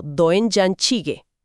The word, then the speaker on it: Doenjang jjigae: Se escribe D-O-E-N-J-A-N, seguido de J-J-I-G-A. Y la pronunciación, según nuestros datos, es algo así como Doenjang Jjigae.